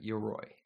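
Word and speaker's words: Yoroi: Is it pronounced Uroi? – I believe so, yeah, Uroi.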